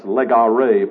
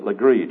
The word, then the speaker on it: Legare: It sounds like it's Legare, but he pronounces it Legree.